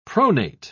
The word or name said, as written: pronate